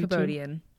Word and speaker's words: Kabodian: Kabodian.